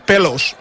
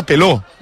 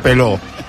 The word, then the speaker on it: Pelos: Doncs és Pierre Pelos, però entenc que serà Peló, no? En francès això potser em pot ajudar. – Sí, seria Peló.